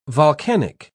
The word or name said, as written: volcanic